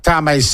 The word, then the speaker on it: Tame: Jack Tame's surname isn't Maori. It's pronounced like the English word tame.